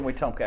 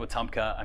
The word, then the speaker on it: Wetumpka: I'm from Wetumpka, Alabama. – I think I'm good there. – It's a bit like Wetumpka, I'm sure, which I don't even know if that's how you say it.